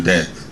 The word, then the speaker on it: debt: Funny thing about money also, I realized that almost all countries in the world are now in debt. Debt. Stupid silent letters. Debt. Why there's B? Debt.